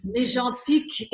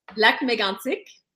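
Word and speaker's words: Lac-Mégantic: But you went to Dawson College and you spent a lot of your youth in Lac Mégantique. – Oh my God, right. You butchered that. Mégantique. Mégantique.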